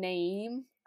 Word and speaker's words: Naeem: Songs, my favorite current songs from Bon Iver is number one, Naeem, I think is how you say it, N-A-E-E-M, Rabbi, and Salem.